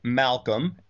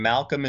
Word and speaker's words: Malcolm: Easiest way is just remember my name, Jack Malcolm, and Malcolm is spelled M-A-L-C-O-L-M. So some people misspell that. Just remember Malcolm X. There's two L's in it.